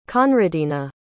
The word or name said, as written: conradina